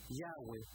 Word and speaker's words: Yahweh: Y el nombre en hebreo es YHWH, muy probablemente pronunciado Yahweh.